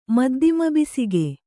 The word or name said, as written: ಮದ್ದಿಮಬಿಸಿಗೆ